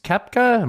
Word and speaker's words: Koepka: This is a golfer named Brooks Koepka. Am I saying that right? – Mm-hmm. Yeah, Koepka.